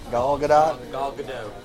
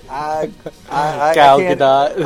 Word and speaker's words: Gal Gadot: Gal Gadot. – Kind of took the show. – Gal Gadot.